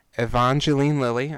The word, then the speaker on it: Evangeline Lilly: Evangeline Lilly, I said that correctly now, I'm proud of myself, as Hope Van Dyne.